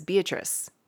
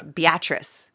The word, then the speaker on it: Beatrice: I thought it was Beatrice, but it's Beatrice. Wow.